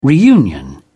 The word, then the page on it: reunion: British English